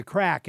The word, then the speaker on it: craic: And maybe you should mention first what the title means, The Crack. And it's spelled C-R-A-I-C, which I think is Gaelic.